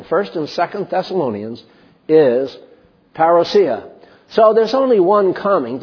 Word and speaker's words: parousia: Then I hear people say parousia, which is all right, but actually the Greek pronunciation is parousia.